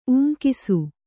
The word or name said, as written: ಊಂಕಿಸು